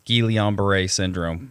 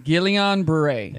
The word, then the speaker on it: Guillain-Barré: So it's taken me a long time to actually figure out how to say it, but I think it's Guillain-Barré syndrome. – Guillain-Barré.